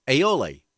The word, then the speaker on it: aioli: Oh, I never know how to pronounce this. Aioli. There we go. It's like Thanksgiving in a roll. – Areola? Is that what you said? – That's Did you say areola? – A-I-O-L-A. Aioli.